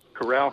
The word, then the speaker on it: Coral: Corral Hotel. – Mm-hmm. Well, actually, it is Coral in English, but it's pronounced, I guess, as Corral.